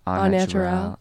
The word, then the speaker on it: au naturel: Like, it's just gonna be au natural. – Yeah. – I did not say that right. – Au natural? – Au naturel. – You said that right.